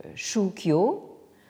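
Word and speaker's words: shukyo (宗教): La complication vient du fait que c'est ce terme de jiao qui a été utilisé pour former un néologisme en japonais, le japonais donc shukyo, prononcé en chinois donc zongjiao.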